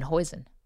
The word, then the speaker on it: hoisin: It's spelled H-O-I-S-O-N, hoisin.